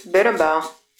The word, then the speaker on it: Berimbau: The word Berimbau is spelled B-E-R-I-M-B-A-U. It is pronounced in three syllables. Berimbau. Berimbau. The tall single string instrument is called the Berimbau.